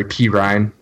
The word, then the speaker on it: Perine: P. Ryan, that's how he pronounces it, which is weird.